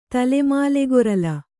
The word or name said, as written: ತಲೆಮಾಲೆಗೊರಲ